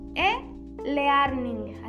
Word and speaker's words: e-learning: En el buscador, en la lupita, hay una lupa así pequeña, ponen e-learning, así se dice. Bueno, se dice e-learning, pero se escribe e-medio L-E-A-R-N-I-N-G, learning, e-learning.